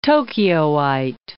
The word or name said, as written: Tokyoite